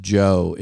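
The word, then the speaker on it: DJO: I believe it's pronounced Dojo or just Joe. It's D-J-O.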